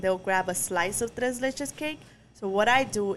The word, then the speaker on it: tres leches: Tres leches. Tres, am I pronouncing it right? – Tres leches.